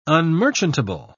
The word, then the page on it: unmerchantable: British English